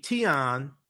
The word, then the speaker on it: tignon: So if we look here, a tion is a headdress pronounced, it's T-I-G-N-O-N, but it's pronounced T-I-Y-N-O-N.